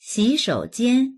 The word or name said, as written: xi3shou3jian1